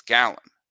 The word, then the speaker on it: Gallen: That's a Gallen or Galen. I always mispronounce it. It's Gallen and Kelly are both terrific.